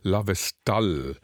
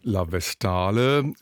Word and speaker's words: La Vestale: In der Neuinszenierung der Oper La Vestale, französisch ausgesprochen, auf Italienisch würde es heißen La Vestale, schildert die Regisseurin den paganen, heidnisch-römischen Priesterkult der Vestalinnen als eine ziemlich totalitäre Sache.